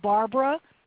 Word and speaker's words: Barbara: which is Barbara, B-A-R-B-A-R-A, and my last name is spelled Godlock. That's G-O-U-D, like in David, E-L-O-C-K.